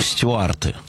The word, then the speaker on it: Стюарты: Наталья Ивановна, вы знаете, я вот в школе почему-то, будучи маленьким глупым очкариком, называл их стюарты. Я всегда ставил ударение стюарты почему-то.